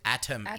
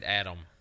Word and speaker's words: Atom: Atom Eve. – Well, it sounded like he said Adam. Well, yeah, but I said it quickly. – Oh. Yeah. Atom Eve. He said Atom, but he pronounced Adam.